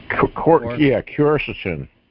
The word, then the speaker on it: quercetin: One, I don't know how to pronounce it. It's quercetin. – Quercetin. – Quercetin, yeah. – Yeah, that's close enough.